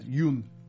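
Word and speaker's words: Yeun: Yeah, they're having the cast screening of the movie Mayhem with Steven, I've never known how to say his name, Yoon from The Walking Dead fame. – Oh, cool. Let's go. Glenn. I think you said it right.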